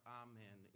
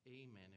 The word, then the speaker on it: amen: Is when we get to those last words there, that's not amen, it's amen. If you just pronounce it that way. And it's hard to give a hearty amen. It just kind of softens that first syllable.